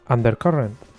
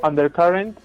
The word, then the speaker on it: Undercurrent: Hoy nos traes Undercurrent. – Qué bien, cómo se nota que estás allá en las islas, que lo pronuncias perfectamente. Eso es, traigo Undercurrent, un manga de Tetsuya Toyoda publicado por Milky Way.